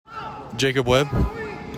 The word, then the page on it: Jacob Webb: JAY kob WEB